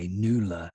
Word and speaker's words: Knuula: Now that's spelled K-N-U-U-L-A, NULA, and it is for engagement letters.